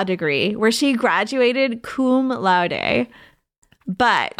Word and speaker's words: laude: Laude, laude. I forget how to pronounce that. – Laude.